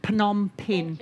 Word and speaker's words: Phnom Penh: That's how you say it, Phnom Penh.